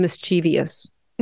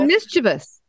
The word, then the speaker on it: mischievous: There's no such word as mischievous. – No. No. – It's mischievous.